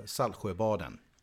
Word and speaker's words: Saltsjöbaden: would you pronounce it? – Uh, Saltsjöbaden. – Okay, so you so get rid of the, the uh, no T in there.